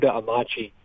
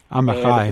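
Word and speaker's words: Amichai: It's by an Israeli poet named, I'm going to mispronounce his name, but Yehuda Amachi. – Amachai.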